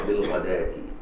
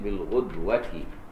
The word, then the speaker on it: بالغداة: لكن باقي القراء الواو تثبت خطا ولا تنطق، تقرأ بالغداة. باقي القراء بالغداة، أما ابن عامر الشامي بالغدوة.